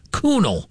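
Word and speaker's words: Kuhnel: K-U-H-N, like John Kuhn, but with an E-L on the end. It's pronounced Kunal.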